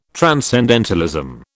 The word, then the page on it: transcendentalism: British English